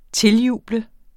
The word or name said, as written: tiljuble